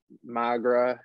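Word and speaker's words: Maghra: It like really starts off our main group of Baba Voss, Magra, I think I'm saying that right. – Yep.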